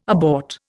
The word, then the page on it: abort: Indian